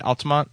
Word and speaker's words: Altamonte: Am I pr- pronounce that right? Altamonte? Yeah and, and Weary Bear.